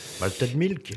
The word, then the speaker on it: Malted Milk: Et ce label distribue le dernier Mighty Mike. Je le prononce bien. – Malted Milk. – Malted Milk. Décidément, tu vois, je suis tombé dans le panneau. – C'est avec l'accent.